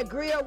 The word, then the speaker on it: Greer: And David Allen Gear. Y'all remember David Allen Gear? Yeah, Greer, whatever. Y'all already know how to pronounce that shit. I just didn't.